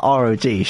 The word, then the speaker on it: ROG: Sorry, I should pronounce that ROG, shouldn't I? – Yes. Yeah.